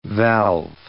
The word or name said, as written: valve